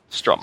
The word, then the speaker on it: Strom: Strom. Pronounce Strom. – Gentlemen. – Norwegian. It's like Swedish Chef. That'll be how I open. That'll be how I introduce myself every episode. I'm just like, I'm Steven Strom.